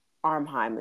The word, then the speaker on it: Ephraim: The, and I think that Willem pronounces it Armheim is how it sounds, but it's mm-hmm. it's E P H R A I M.